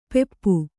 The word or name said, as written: ಪೆಪ್ಪು